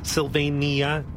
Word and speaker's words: Sylvania: Sylvania? I think it's Sylvania. – It's four syllables, Sylvania.